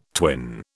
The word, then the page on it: twin: British English